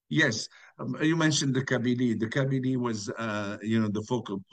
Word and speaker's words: Kabylie: There's an area called, I'm not sure if I'm pronouncing it correctly, if it's Kabylie, but Kabili, Kabili. Yes.